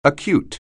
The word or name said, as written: acute